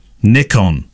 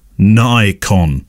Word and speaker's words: Nikon: Nikon, as in Nick on, not Nikon. We aren't American, and I'm not sure why this American pronunciation has crept in. For decades, we've said Nikon. I believe that the Japanese pronounce N-I as ni, Nikon.